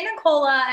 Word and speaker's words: Nicola: And people ask me all the time, they're like, so it's spelled Nicole, but then you say Nicola and I'm like, call me what you want, but it's yeah.